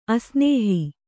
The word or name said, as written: ಅಸ್ನೇಹಿ